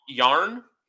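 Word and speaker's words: Järn: So it's like C-A-L-I, but L-L-E. – Callie. – Yarn. Like a ball of yarn, but it's spelled with a J instead of a Y.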